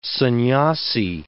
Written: sannyasi

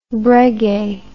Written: breguet

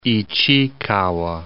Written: Ichikawa

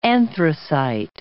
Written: anthracite

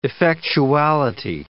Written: effectuality

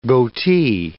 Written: goatee

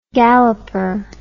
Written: galloper

